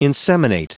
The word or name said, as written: inseminate